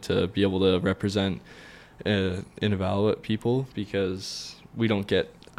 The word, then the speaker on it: Inuvialuit: So you represented Inuvialuit. Is that how you say it? – Can you say it? Yeah.